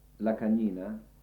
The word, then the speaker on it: Lacagnina: For instance, there is a composer called Oliviero Lacagnina he wrote two concerts for me, one for... – How do you spell it, just so when I transcribe? – Lacagnina, L-A-C-A-G-N-I-N-A.